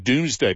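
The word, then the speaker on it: Domesday: I mean, it sounds like doom, Doom, Yeah. – doom. So you hear it for the first time and think Doomsday Book. What was that about? Everybody was doomed, but it's spelt Domes, which must have meant something different.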